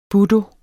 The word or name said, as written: budo